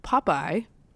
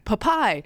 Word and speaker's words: Popeye: She has always called Popeye, Popeye. Like with the emphasis on the wrong wrong syllable. – Popeye.